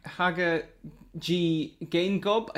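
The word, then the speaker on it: Hage Geingob: I don't know if I'm pronouncing his name right, but it's Haga G. Gaingob, I think is the pronunciation. – Yeah. – You did so well there.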